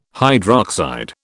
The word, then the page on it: hydroxide: British English